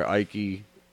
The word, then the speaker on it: Icke: David Icke, or Icke. – I-K-E. – Yeah, you know what I mean? It's pronounced quite a few different ways, you know what I mean? But for him, I'm sure it's one way.